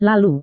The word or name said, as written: lalu